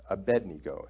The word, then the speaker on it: Abednego: Abednego is actually how you said it, how you say it.